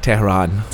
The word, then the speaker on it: Tehran: Tehran.